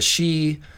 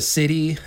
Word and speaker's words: sidhe: And it was, of course, your video about the she of Irish folklore, the city, as they are sometimes mispronounced. Mm-hmm. S-I-D-H-E, it's that weird word.